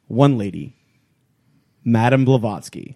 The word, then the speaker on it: Blavatsky: Blavatsky was awesome. – I'm so proud of you for saying that. I said it right.